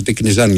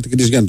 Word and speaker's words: Τικνιζιάν: Ο Τικνιζιάν, γιατί δεν προφέρεται, λέει, είναι με το Αλφα. Τικνιζιάν λοιπόν να το λέμε. – Όπως ναι, εντάξει ρε παιδί μου, και εγώ δεν το ξέρω. Τικνιζιάν το λέω.